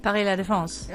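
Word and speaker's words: Paris La Défense: God, I'm going to butcher this pronunciation. My apologies to any French people listening. – Oh, Paris-La-Défense in France? Where? – Paris-La-Défense. – Yeah! Thank you.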